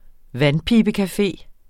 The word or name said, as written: vandpibecafé